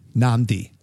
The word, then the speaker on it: Nadi: Is spelled N-A-D-A. – Oh, N-I-D-A. Sorry. I'm sorry. N-A-D-I. – And it sounds like you're adding another N in there. – Yes. Before D's, you add another N.